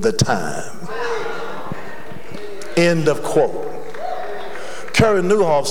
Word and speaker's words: Nieuwhof: One of the most prolific and powerful books that I have in my library is a book by Cary Newhoff. His last name is spelled N-I-E-U-W-H-O-F.